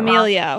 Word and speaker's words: D'Amelio: Okay, so the DeMayo sisters, my sister's going to kill me for probably pronouncing that D'Amelio. – Wrong. D'Amelio.